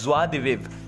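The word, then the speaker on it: joie de vivre: but ऐसे है इसका प्रोनाउंसिएशन असली में जो है उसको बोलते हैं ज्वा दिविव ज्वा ज्वा स्वाहा स्वा उसे ज्वा दिविव ज्वा दिविव विव बोल के छोड़ देते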